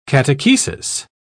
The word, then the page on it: catechesis: British English